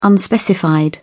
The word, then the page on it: unspecified: British English